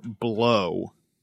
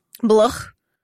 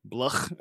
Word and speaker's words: Blough: It's a human entity named David Blow. – David Blow. – I'm assuming it's pronounced blow. It's spelled B-L-O-U-G-H? – Yes. – Bluch. – Bluch. – That's good. That's perfect.